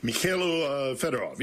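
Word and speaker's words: Mykhailo Fedorov: Who is and what's the situation with Mikhailo Fedorov? Am I saying that right? – Mikhailo Fedorov, yes.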